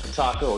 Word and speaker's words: Kataro: Is I said that right? – Close enough. Kataro, or you going to be KG for short.